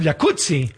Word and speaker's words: jacuzzi: Entonces deberíamos pronunciar el jacuzzi. – Exacto. – Como pizza. Como Rampazzo, como Claudio Rampazzo.